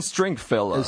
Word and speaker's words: Stringfellow: Ken Stringfellow.